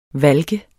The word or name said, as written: valk